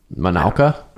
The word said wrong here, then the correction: Mauna Kea: It's Mauna Kea. – Mauna Kea. – Yeah.